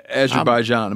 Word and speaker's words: Azerbaijan: But like Azerbaijan, am I saying that right? – Yeah.